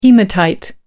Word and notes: haematite: American English

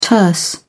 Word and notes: terse: British English

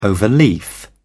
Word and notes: overleaf: British English

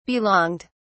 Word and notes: belonged: British English

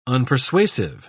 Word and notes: unpersuasive: British English

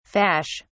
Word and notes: fash: British English

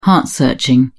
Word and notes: heart searching: British English

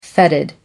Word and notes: fetid: American English

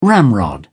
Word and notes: ramrod: British English

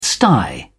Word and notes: sty: British English

